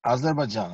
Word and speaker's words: Azerbajdzjan: Azerbajdzjan, ja, precis.